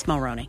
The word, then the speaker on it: Mulroney: Yeah, it's Mulroney. – Mulroney. – Only Brian Mulroney pronounces it that way.